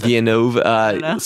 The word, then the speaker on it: Villeneuve: Villeneuve.